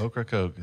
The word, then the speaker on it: Ocracoke: battle at, I can't pronounce Ocracoke? – Ocracoke, yeah.